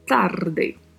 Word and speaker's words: tarde: Já falando da pronúncia de tarde, também temos a letra A aberta e a letra E fechada e o vibrato do R. Além do som do DE, que tem som de D não tem o som de, como a gente conhece no português. Então fala-se de, tarde.